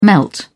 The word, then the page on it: melt: British English